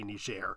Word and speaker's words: Niger: And in fact, not recognizing the fact that the French had decided it would be pronounced Niger. You see what I'm saying? – Mm-hmm. – And she got mad at him because she said, we pronounce it here in this country, Niger. You're not being anti-colonialist by saying Niger to sound like American or something.